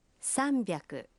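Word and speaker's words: Самбяку (さんびゃく): Самбяку.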